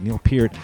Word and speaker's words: Neil Peart: Neil Peart's kit, I'll say his name correctly, Neil Peart, the kit that's on the cover of All the World's a Stage.